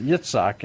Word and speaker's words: Yitzhak: Yeah, but it's pronounced Yitzhak in Hebrew, of course.